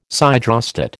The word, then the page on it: siderostat: American English